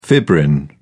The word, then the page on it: fibrin: British English